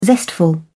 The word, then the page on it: zestful: British English